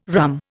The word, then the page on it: rhumb: Indian